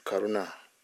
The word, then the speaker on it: karuna: Karuna, long A. Not a short one, so I don't know near dawn. There's metta, karuna, mudita, I think also upeka. But karuna, all those words actually, it's a long A at the end.